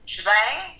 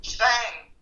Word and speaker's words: Zwang: You were finding the, is it Schwang? Schwang? Am I saying that right? Zwang? C-W-A-N-G? – Uh, äh, Schwang? Say it again. Is that, a, is that correct? – Say it again. Zwang.